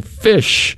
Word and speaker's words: Phish: We're going to kick off with a group called Phish. That's P-H-I-S-H.